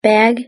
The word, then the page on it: bag: British English